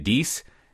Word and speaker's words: Deis: But they bought, how do you say it, Deus? There you go.